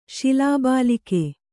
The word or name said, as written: ಶಿಲಾಬಾಲಿಕೆ